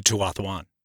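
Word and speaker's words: Tuatha'an: With the Tuathuan. – Thank you for pronouncing that correctly, by the way.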